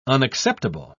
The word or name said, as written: unacceptable